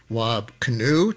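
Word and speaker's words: Wab Kinew: Wab Kinu, to say his name accurately, Premier of Manitoba, showing a little solidarity with Ontario, the province to its east.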